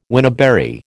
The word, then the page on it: wineberry: American English